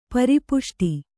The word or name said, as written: ಪರಿಪುಷ್ಟಿ